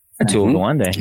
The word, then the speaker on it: Atul Gawande: Atul Gawande, yeah.